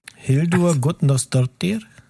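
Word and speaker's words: Hildur Guðnadóttir: Hildur Gudnarsdottir.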